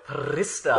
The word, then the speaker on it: Pfister: I'm going to go with Pfister. – That's good. I like that. – Yeah, it's good. – It rhymes with barista.